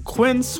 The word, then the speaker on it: Quince: Today Explained's support today comes from Quince, which rhymes with since, but is spelt with a Q-U.